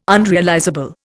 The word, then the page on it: unrealizable: Indian